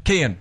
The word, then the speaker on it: Kian: They're saying if you can put the key in the door, then you can say his name. – Kian.